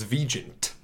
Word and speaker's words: Vigeant: But anyway, a handful of the older alumni were like, we have been pronouncing it Vigeant forever. And I was like, please continue. I have no problem with that. It sounds way more sophisticated that way. – It's absolutely the way it's supposed to be pronounced. It's just, you know, we moved here and somebody decided it's Vigeant.